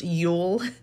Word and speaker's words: Yule: And it was originally called Jule, J-U-L, but I believe that is pronounced Yule anyway, and it takes place on the longest night of the year.